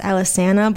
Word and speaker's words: Alesana: I think it's actually pronounced Alesana, but you will never catch me saying that. You're gonna hear me say Alesana because that's how I grew up listening to saying it.